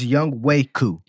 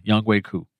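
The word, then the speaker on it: Ngakoue: Apparently, we've been saying his name wrong. It's Young Waku. – It is from the Falcons. Yeah, Young Waku.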